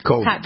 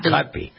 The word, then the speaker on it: Kotb: Kotb. – Kotb.